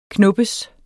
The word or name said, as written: knoppes